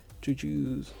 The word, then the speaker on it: juju: Juju zombies. Is that how you pronounce it, by the way? Yeah. I've just been saying that. – No, it's juju. Like juju bees.